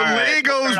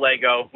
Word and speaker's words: Lego: I didn't know this was a thing because the Legos pronunciation drives me insane. – Yeah, we just call Lego. We just say Lego. We'll start off with some Monday controversy, but yeah, just Lego. No S needed.